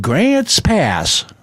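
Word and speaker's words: Grants Pass: We played Grant's Pass. That's how you say it.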